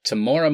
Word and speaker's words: Temuera: Because we see, I think I'm pronouncing his name right, Temuera Morrison? – Temuera Morrison, Aquaman's dad.